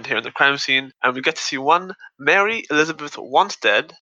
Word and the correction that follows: Winstead: Is it Wanstead? Oh god, wait, I think I got her name wrong. Wait, cut this bit out. – I don't know. Was it Helena? – Yeah. And Mary Elizabeth Winstead, not Wanstead.